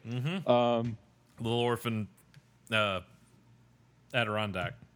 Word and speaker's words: Adirondack: It's pronounced Adirondack.